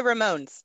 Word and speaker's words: Ramones: I got to go see the Ramones. Is that how you say it? Because we call them Ramones. – It's the Ramones.